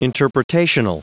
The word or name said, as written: interpretational